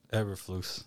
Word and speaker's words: Eberflus: And then I think the Indy DC – how do you pronounce his last name? Eberflus? – Eberflus.